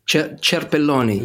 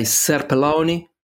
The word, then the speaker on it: Cerpelloni: Because do you want the Italian pronunciation or the English pronunciation? So sometimes I say Cerpelloni, which doesn't, you know, I don't identify with that.